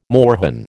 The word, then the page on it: moorhen: American English